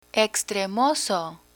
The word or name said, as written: extremoso